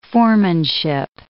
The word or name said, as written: foremanship